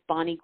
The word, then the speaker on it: Bonni: It's just Bonnie Goldstein, MD, and my first name is spelled without an E at the end, B-O-N-N-I, and then Goldstein, MD.